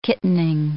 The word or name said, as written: kittening